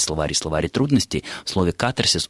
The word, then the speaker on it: катарсис: что слово катарсис, ударение должно ставиться только на первом слоге, а в большинстве европейских языков и в древнегреческом ударение ставилось на второй. Я специально забрался вчера в словарь Вейсмана древнегреческо-русский, и действительно в древнегреческом языке ударение ставилось на второй слог, катарсис. В английском это слово произносит ударение на второй слог, остальное я не успел проверить. Но, Нина, значит, если говорить о норме, о современном русском литературном ударении, то все-таки постановка на второй слог ударения считается ошибкой.